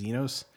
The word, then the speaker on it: Xenos: But he highlights that in Matthew 25, Jesus identifies as, I think it's how you say it, is Xenos. This is X-E-N-O-S, and that when you dive into what that word means, basically he's the guest and the host.